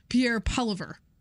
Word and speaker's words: Poilievre: I believe he says in the video we're going to play for you, Pierre Poilievre.